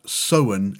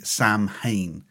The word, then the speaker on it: Samhain: I'm led to believe that Samhain is correct, even though the spelling suggests it should be Samhain. It's spelled S-A-M-H-A-I-N.